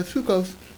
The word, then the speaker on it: Sukkos: So again, the first 2 times there's no vav, the third time there is a vav, but it's pronounced by Sukkos all 3 times.